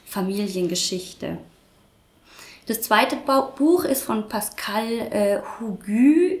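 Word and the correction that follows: Hugues: Ich äh, werfe einen Blick zur Marie, weil ich nicht weiß, ob ich es richtig ausgesprochen habe. Pascal Hüge. Hüge, Dankeschön.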